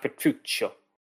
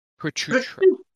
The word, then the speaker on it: Petruchio: It's pronounced Petruccio. Petruccio.